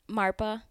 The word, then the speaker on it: Marpa: Marpa.